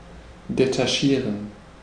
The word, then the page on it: détacher: France; [de.ta.ʃe]